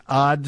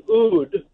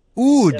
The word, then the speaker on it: Odd: The mayor's name is Odd Strangeland. – And listener, we're saying that wrong, aren't we? – Yeah, you're saying it wrong. The way they pronounce it in Norway is Oud. – Oud Strangeland.